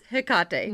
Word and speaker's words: Hecate: and the original way is Hecate.